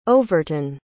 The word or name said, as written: overton